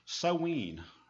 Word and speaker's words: Samhain: Now, I'm going to spell this for you because it is not pronounced at all the way it's spelled. It's spelled S-A-M-H-A-I-N. Now, you think that'd be Samhain or something like that. It's actually pronounced Sowen or Sowen. S-O-W-E-N.